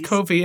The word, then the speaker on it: Ko-fi: Anyways, that's how they say a cup of coffee. But anyways, it's pronounced Ko-fi.